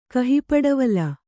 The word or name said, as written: ಕಹಿಪಡವಲ